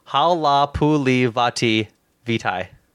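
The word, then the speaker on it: Halapoulivaati Vaitai: Okay, I'm gonna take the second here and pronounce this this right guard's tackle this right guard's name right because I feel like a dickhead. Hala Puli Vati Vitai. – There you go.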